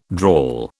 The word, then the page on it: drawl: British English